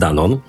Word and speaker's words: Danone: No i ten 30% to Państwo pewnie też znają, bo to jest Danone. Dla niepoznaki w Stanach Zjednoczonych, żeby wymawiali dobrze, to ten koncern się pisze D-A-N-N-O-N-Y, czyli trochę inaczej niż u nas, ale tak, żeby się dobrze wymawiało.